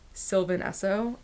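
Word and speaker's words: Sylvan Esso: Sylvanesso. Oh, it's, like, that's how you pronounce it. Like, Sylvan space Esso, like the gas station. – Yeah, Sylvanesso.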